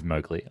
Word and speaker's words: Mowgli: Moggle becomes accepted by the animals of the jungle. – Actually, I've just double checked. It is Mowgli. I'm sorry. I was mispronouncing it that whole film.